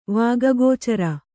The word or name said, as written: ವಾಗಗೋಚರ